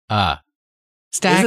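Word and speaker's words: Stakataka: Why can't you say stack attack? Stack attack. Is that attack-a. How it's said? – Stack attack. – That's all it is. It's a stack of things that attack you.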